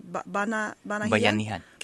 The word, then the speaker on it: Bayanihan: Mr. Mayor, one of the things you mentioned was, I hope I'm pronouncing the word in Tagalog right, Bayanihan.